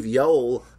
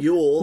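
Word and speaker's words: Yeowell: Steve Yoel, not Yule, like, as in Christmas. – It rhymes with Noel or something. – Noel, yes. Yeah. – Yeah, that's good. Yoel.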